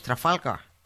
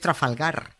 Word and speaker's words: Trafalgar: Que no es Trafalgar, que es Trafalgar.